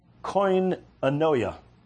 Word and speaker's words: koinonia: The Greek word translated fellowship here is koinonia. It means sharing a common or having a communion with one another. It's spelled K-O-I-N-O-N-I-A.